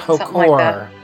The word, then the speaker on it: Hautecourt: Hautecourt. How do you say that in French? – Like Haucourt. Yeah.